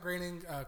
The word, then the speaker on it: Groening: Groening. – Is that how you say it? – Yeah, it rhymes with raining. – I didn't know that. – Yeah. – Matt Groening, creator of The Simpsons, is confident another Simpsons movie is coming because they started really working on it last year.